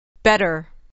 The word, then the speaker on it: better: Better. Better. Better. Better. – better در اینجا و در خیلی موارد ت مخصوصا در تلفظ آمریکایی به صورت د نازک تلفظ میشه بگید